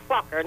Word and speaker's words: Fokker: It is Fokker. Yeah, like a very short as in TikTok. Fokker. – Fokker. – It is scary, but that's what it is. Fokker, not Fokker or anything else.